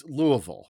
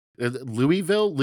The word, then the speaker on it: Louiseville: And so this is the same thing, but in Quebec, and there's an E, so it's pronounced, or excuse me, it's spelled L-O-U-I-S-E-V-I-L-L-E, Louisville, Louis. – I think that would be Louise. – Louisville. – Louisville, Quebec. – I think that's it. Yeah, I think we nailed it together.